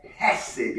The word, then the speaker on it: chesed: Is this particular word pronounced more like a, it's more like a CH sound, but a, more like a, so a harsh, a chesed or chesed. You know, that's be kind of how it's pronounced.